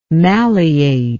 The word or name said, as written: malleate